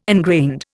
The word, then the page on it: ingrained: Indian